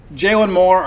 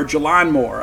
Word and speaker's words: Jalon Moore: Jalen Moore, or Jalon Moore, I guess is the correct pronunciation, averaging 17 points a game.